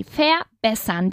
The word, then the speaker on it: verbessern: Y pronunciamos así, verbessern.